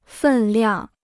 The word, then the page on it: 分量: fèn liang